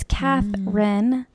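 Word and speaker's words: Cathrine: It's Katherine, no E in the middle.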